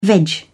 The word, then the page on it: veg: British English